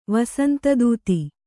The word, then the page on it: ವಸಂತದೂತಿ: vasanta dūti